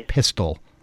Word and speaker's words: Pistole: Yet, despite that, this morning, TSA Director John Pistole, that's right, it's spelled P-I-S-T-O-L, pronounced like pistol. – That's really his name. His name is Pistole.